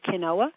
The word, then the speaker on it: quinoa: So a healthy non-gluten diet would be, the best grains would be quinoa, Q-U-I-N-O-A. It's not said the way it's spelled. Everybody says it a little bit different. Some people might say it quinoa, but I think the way that it's derived is quinoa.